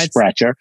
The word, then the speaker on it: Sprecher: I think it's Sprecher.